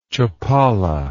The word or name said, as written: Chapala